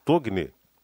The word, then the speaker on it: Togni: Então, quem encontrar, favor, devolver aqui na Gazeta documentos de Solange Adelina Moresco Tonhi ou Togni, né, como se diz, Togni, como se escreve, aliás, T-O-G-N-I.